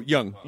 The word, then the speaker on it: Jung: Like as you get older, you kind of, it's that is it Jung? Is that how you say his name? The psych. – Oh, Jung. – Jung, thank you.